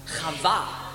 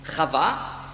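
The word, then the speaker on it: Chava (חוה): Und der Mensch gab seiner Frau den Namen Chava. Eva, denn sie war die Mutter aller Lebendigen. Chava, in Griechisch ausgesprochen Eva, heißt Leben.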